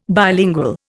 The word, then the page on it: bilingual: Indian